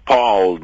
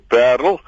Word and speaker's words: Paarl: Parelit en dit word natuurlik gespel P A A R L en in Afrikaans is die uitspraak nie soos in Engels paal nie maar wel perl en dit word behou ook in die in die persoonsnaam perliet.